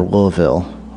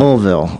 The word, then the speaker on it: Louisville: Chad lives in Louisville, or Louisville, I'm supposed to say. Damn. – They want less syllables on that. – Louisville.